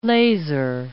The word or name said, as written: laser